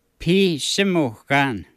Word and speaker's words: Pisimukan: In Michif, how do you say clock? – Bisimukan. – Bisimukan. – Pisimukan. – Pisimukan. – Yeah.